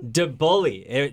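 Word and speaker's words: Deboullie: DeBully. Pretend it's spelled D-E-B-U-L-L-Y. It isn't, but that's how we say it here in Maine.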